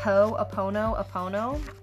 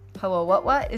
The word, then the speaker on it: Ho'oponopono: Also in my book, I discuss the Ho'oponopono, and I'm so proud that I can actually say it right, because before, I could not. Ho'o-what-what is what I would be like.